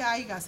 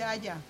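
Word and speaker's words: haya: Cuando yo empecé a juntarme con Javier Acosta, no se dice Aiga, se dice Aya.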